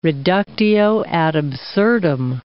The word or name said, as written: reductio ad absurdum